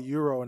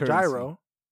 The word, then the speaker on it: gyro: Let's talk about Euro and how it has a G and a Y and it's pronounced like an E and a U.